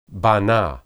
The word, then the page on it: banah: Hebrew